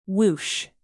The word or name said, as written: whoosh